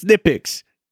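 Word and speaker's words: Knipex: So now now now you have uh a good you're probably gonna have a good swarm of people like, no, it's nitpicks. The K is silent.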